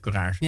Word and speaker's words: Courage: Spreek je het op zijn Frans uit of op zijn Engels? Ja, op zijn Frans. – Theater Hotel Courage.